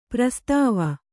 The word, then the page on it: ಪ್ರಸ್ತಾವ: prastāva